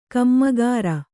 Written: ಕಾಮಕರ

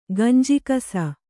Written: ಗಂಜಿಕಸ

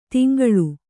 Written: ತೆಂಕಳ

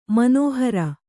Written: ಮನೋಹರ

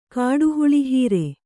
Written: ಕಾಡುಹುಳಿಹೀರೆ

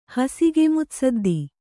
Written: ಹಸಿಗೆಮುತ್ಸದ್ದಿ

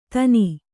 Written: ದನಿ

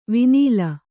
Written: ವಿನೀಲ

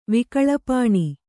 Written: ವಿಕಳಪಾಣಿ

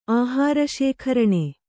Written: ಆಹಾರಶೇಖರಣೆ

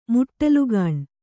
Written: ಮುಟ್ಟಲುಗಾಣು

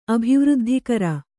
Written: ಅಭಿವೃದ್ಧಿಕರ

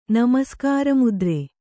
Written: ನಮಸ್ಕಾರಮುದ್ರೆ

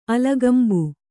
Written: ಅಲಗಂಬು

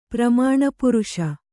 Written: ಪ್ರಮಾಣಪುರುಷ

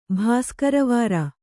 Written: ಭಾಸ್ಕರವಾರ

